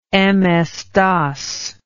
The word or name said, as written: MS-DOS